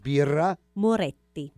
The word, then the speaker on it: Birra Moretti: Let's say it right Birra. – Birra. Birra. – Moretti. – Birra Moretti. – Oh. – Birra Moretti. Ah, Bravo. vabbè. So the two T.